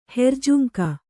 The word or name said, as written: ಹೆರ್ಜುಂಕ